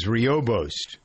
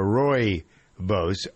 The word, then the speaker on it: rooibos: It's called red tea, also known as rooibos or rooibos, R-O-O-I-B-O-S, pronounced either way, has a lot of health benefits.